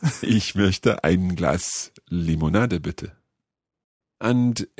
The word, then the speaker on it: möchte: The word would be ich möchte. – Ich möchte. That's quite a tricky vowel sound to make there.